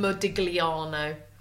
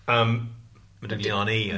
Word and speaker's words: Modigliani: Modigliano. – Modigliani, I think. – Modigliani, whatever. – And I don't think you pronounce the G.